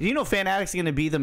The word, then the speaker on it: Fanatics: How did you say that? Fanatics? – Fanatics. That's the company. – Fanatics. – Yeah. – It's not Fanatics? – Well, it's two words. – Is it like that double entendre type of thing? Yeah. Oh, Fanatics, Fanatics. Okay, I thought you were just putting emphasis on the wrong part of the word. – Fanatics. – Fanatics. Okay, I get it now. – Yeah. It's like, yeah, it's a play on words.